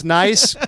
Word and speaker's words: Nice: Oh, is it nice? – It's pronounced nice, yeah. – Just the like city's so nice, they called it Nice.